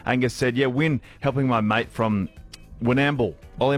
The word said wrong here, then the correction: Warrnambool: I believe it's Warrnambool. – Warrnambool. – Yeah. Yep. – Oh, I'm saying it right.